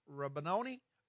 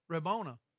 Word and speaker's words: Rabboni: She turned and said to him in Hebrew, and I think this is pronounced Rabboni. Speak it out loud. Rabona. Very good.